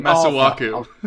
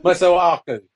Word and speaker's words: Masuaku: I mean, I can never say his name, Masueke. What's his name? – Masuaku. – Masuaku.